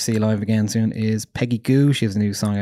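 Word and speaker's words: Peggy Gou: because Peggy Goo rhymes with shoe, and it happened at Glastonbury one year.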